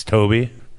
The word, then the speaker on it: Tobe: I was going to ask you how you pronounce it because he spells it T-O-B-E. – Sometimes people shortened up the Toby. They went with Tobe. And I was like, why? It's Toby.